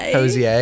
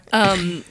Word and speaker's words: Hozier: Hozier. Is that actually how you say it? – No, I don't think so. I think it's Hozier.